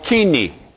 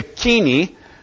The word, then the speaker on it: koine: Now, Greek, proper Greek with proper grammatical expression and proper punctuation is called kini. It's not spelled that way, but that's the way it's pronounced. K-O-I-N-E is the way it's spelled in English, and that looks like coin, but the pronunciation is kini.